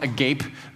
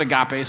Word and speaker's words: agape: I know, I know it's spelled, it looks like it's spelled agape, but they pronounced it agape, so I'm just going to go with that.